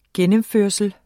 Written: gennemførsel

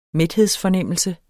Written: mæthedsfornemmelse

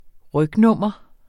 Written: rygnummer

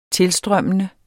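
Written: tilstrømmende

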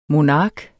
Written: monark

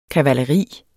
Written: kavaleri

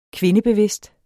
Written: kvindebevidst

